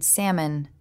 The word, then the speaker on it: salmon: If she pronounces the L in salmon.